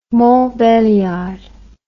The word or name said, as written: Montbéliard